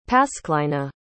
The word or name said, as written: pasclina